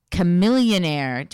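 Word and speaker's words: Chamillionaire: Chameleonaire.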